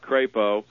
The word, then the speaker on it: Crapo: Is that Crapo or Crapo, you said? – I'm going to call it Crapo. – Right. – That's the way he pronounces it.